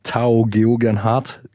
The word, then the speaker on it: Tao Geoghegan-Hart: Tao Geoghegan-Hart,